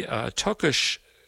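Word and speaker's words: Takács: After that, we're going to hear something very different from the Tokash Quartet. It's spelled T-A-K-A-C-S.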